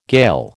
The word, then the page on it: Gael: American English